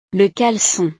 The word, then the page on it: le calecon: frances